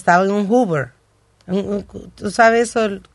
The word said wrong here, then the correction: hoverboard: No se dice Hoover. – Hover, hoverboard. – Uh, hoverboard.